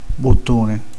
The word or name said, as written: bottone